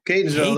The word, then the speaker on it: Catonsville: Catonsville. There, you want to put an N at the beginning. We all do. But there's no N at the very beginning.